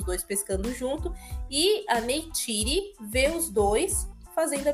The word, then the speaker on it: Neytiri: Neytiri. Neytiri,